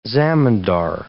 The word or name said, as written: zamindar